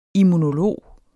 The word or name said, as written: immunolog